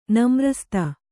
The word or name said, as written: ನಮ್ರಸ್ತೆ